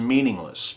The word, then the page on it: meaningless: American English